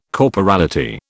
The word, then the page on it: corporality: British English